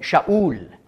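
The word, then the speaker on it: Shaul (שאול): Auf Hebräisch ausgesprochen Shaul, das heißt der Gefragte, der Begehrte.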